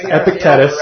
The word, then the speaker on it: Epictetus: Epictetus. – Epictetus.